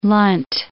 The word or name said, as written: Lunt